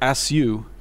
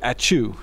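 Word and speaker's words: ačiū: But as I was looking at it, it looked like "asu." So I said "asu" or "asu," and she said "achu."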